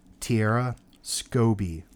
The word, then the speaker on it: Tiera Skovbye: Tiera Skovbye is how they mm-hmm.